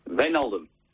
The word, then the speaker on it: Wijnaldum: Wijnaldum.